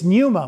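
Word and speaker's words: pneuma (πνεῦμα): In Greek, it's pneuma with that silent P at the beginning,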